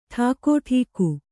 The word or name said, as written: ಠಾಕುಟೀಕು